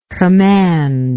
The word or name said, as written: commandless